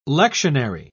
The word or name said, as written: lectionary